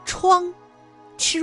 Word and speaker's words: 怆: 然而这个“ 怆” 字啊，它还有其他的读音，比如一声，chuang，ch u ang chuang，表示悲痛凄怆的意思。